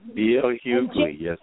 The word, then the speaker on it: Hughley: It's the new NBC show, Heartbeat, starring Melissa George, Dave Annabelle, D.L. Hughley. Am I saying it right? – Hughley. – Hughley? Jesus. – D.L. Hughley, yes, ma'am.